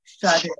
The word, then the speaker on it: Sade: Sade.